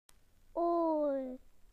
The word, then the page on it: ear: British English